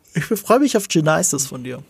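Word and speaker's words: Genisys: Ja, das heißt nächste Woche äh, Salvation, dann Gen Genesis, so müsste man ihn eigentlich aussprechen, weil ein Y drin ist.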